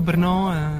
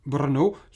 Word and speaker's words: Brno: Comme souvent, lorsqu'un mot est composé d'une suite de consonnes difficiles à sortir de la bouche sans risquer un claquage de la langue, les Tchèques cèdent en intercalant un E muet entre deux consonnes. Dans le cas de Brno, ce E muet se trouve, vous l'avez peut-être reconnu ou entendu, entre les deuxième et troisième consonnes. Quant à l'étymologie,